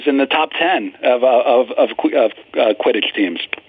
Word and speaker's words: Quidditch: 2100, my bet's on Kittich. Is that how you pronounce it? Quidditch? – Quidditch, John. Come on.